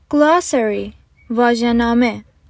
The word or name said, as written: glossary